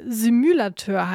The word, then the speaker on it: Simulateur: Eigentlich, da müsste es wohl Simulateur heißen, aber Joshua alias Indio, der hat während seines Erasmus-Semesters in Brüssel dann doch nicht alle Aussprachefehlerchen ausbügeln können.